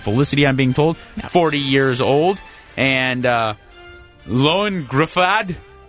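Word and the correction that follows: Ioan: Ian. I think it's just pronounced Ian.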